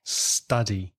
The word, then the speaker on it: study: Just one thing I'd like to stress, and that's the pronunciation of the verb study. The vowel sound is a, the same as up. So a study. I'm studying.